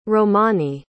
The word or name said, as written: romani